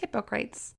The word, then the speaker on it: Hippocrates: Say it to me. His name? – Hippocrates. Isn't it spelled the same as hypocrites? – Yes.